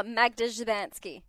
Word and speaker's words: Magda Szubanski: Oh, Magda Szubanski.